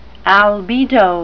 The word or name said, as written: albedo